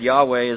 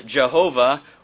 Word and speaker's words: Yahweh: Again, as I said, Yahweh is our best estimation as to how that name is pronounced. Some have pronounced it Jehovah or Yehovah, but most agree it's pretty clear that's not an accurate rendition of that name.